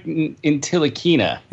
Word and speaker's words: Ntilikina: Frank Ntilikina.